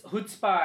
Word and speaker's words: chutzpah: There's a scoopoo particle in chutzpa. – And actually, they pronounce it wrong. It's chutzpah, actually, if you... It's Hebrew.